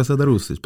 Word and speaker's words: Serdarušić: Ja, det är riktigt, Noka Serdarušić, bra uttal.